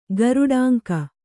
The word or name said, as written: ಗರುಡಾಂಕ